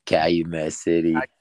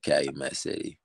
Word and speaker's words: Calumet City: But going back to kind of where the beginning of where everything started, you grew up in Calumet City, Illinois. Is that correct? – Calumet City.